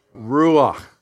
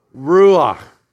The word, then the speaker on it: Ruach: Ruach. Okay, together. Ruach.